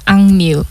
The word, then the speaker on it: AKMU: So next up, we have Fishing in the Water by Akmu. Maybe do you want to introduce it? – Yeah. Okay, that is the name is Angmu. – Ah, okay, sorry my pronunciation.